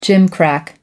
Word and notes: gimcrack: American English

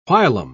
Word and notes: whilom: British English

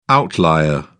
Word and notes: outlier: British English

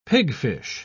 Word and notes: pigfish: British English

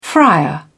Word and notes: frier: British English

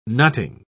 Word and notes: nutting: British English

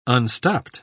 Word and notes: unstopped: British English

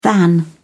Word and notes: than: British English

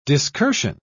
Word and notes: discursion: British English

